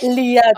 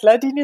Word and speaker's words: Lyadini: Liadini. – Okay, krass. – Liadini, also, nicht Ladini, sondern Liadini, aber davor war es richtig.